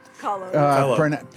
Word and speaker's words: Kahlo: Kahlo, is that how it's pronounced? – Kahlo.